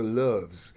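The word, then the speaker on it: Loews: If you ask anybody who remembers it, it was opened up as a Lowe's hotel, but nobody in Monte Carlo or Monaco ever pronounced it that way. They still call it the Loves. It's the Loves Hotel.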